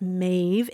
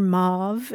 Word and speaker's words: Maeve: And you explain again and again that it's Maeve, M-A-E-V-E, and it rhymes with wave. But grandma accidentally calls her Mauve, and you're constantly trying to explain yourself to the point where now you're in tears.